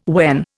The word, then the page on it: when: Indian